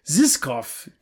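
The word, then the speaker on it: Žižkov: I'm sure I totally Z-I-Z-K-O-V. – Zizkov. – Zizkov TV Tower. – You got to say it like a Bond villain. Come on. – Yeah, Zizkov. – There we go.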